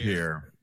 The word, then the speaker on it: Pierre: Yeah, we call our South Dakota the state capital Pier. I mean, we butchered that shit. You're just going to get something more dumb and literally pronounced. – Yeah, we did. It's not Pierre. It's Pier.